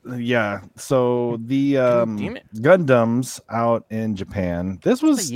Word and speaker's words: Gundam: Well, next up, I want to talk about what's this Gundam thing you're talking about in your leg? – So Gundams.